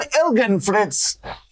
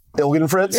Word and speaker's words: Ilgenfritz: Let's welcome Evan Ilgenfritz to Ignite. – How's it going, everyone? Good to be here. Happy to talk about LSAs. – Did I pronounce it right? – Ilgenfritz? – Yeah.